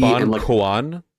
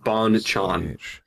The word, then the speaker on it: Bonchon: Is it Bon Kuan? Like C-U-O? – No, it's spelled Bonchon. Banchan.